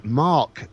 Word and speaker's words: marc: It's written as MARC. How do you pronounce it? – What, my name or grape? – The grape. Mar.